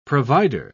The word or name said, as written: provider